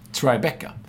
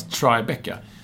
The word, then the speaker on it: Tribeca: Tribeca. Det är svårt att veta att det ska uttalas Tribeca. När man läser det T-R-I-B-E-C-A. Mm. Det är ju lätt att tro att det blir Tribeca.